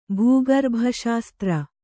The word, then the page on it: ಭೂಗರ್ಭಶಾಸ್ತ್ರ: bhū garbha śastra